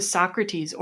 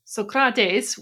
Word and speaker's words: Socrates: We now come to Socrates, or rather Socrates, which apparently is the way you're supposed to pronounce that.